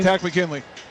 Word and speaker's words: Tack McKinley: How do you say his name? – Yeah, Tack McKinley.